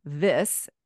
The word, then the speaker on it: Viss: I think I'm just at Leila Viss and Leila, L-E-I-L-A. And then the last name, is Viss. V as in Victor, I-S-S as in Sam.